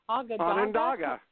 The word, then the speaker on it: Onondaga: There I learned about salt history in Lake Onondaga. I'm not sure how to pronounce the lake. – Onondaga.